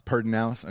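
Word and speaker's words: Pedernales: Is it Perdinales? Am I saying that right? – Perdinales. – Okay, yeah. Perdinales.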